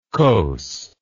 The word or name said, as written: kos